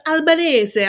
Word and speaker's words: Albanese: It was like, do people in your own family pronounce it differently? – They go Albanese. I'm not going to do that. – You know that if there's any, you know, network that would respect the hell out of that, though, it's SNY. – Oh, absolutely. But like, I had to tell them, no, don't try the Italian way. Don't even worry about it.